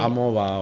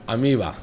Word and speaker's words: Amoeba: Amigo, Amoba o algo así. – Sí, Amoeba. La de... Ah, sí. – Amiba, se dice. – Amiga. – Amiba. Se escribe Amoeba.